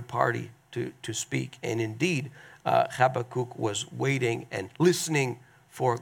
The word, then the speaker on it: Habakkuk: So last week we started to get into the answers that God gave to this prophet, Habakkuk. I know it's hard to pronounce his name, but that's how you pronounce it.